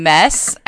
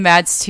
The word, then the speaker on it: Mads: I'm pretty sure you say his name Mess. I think he said that in an interview.